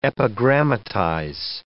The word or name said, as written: epigrammatize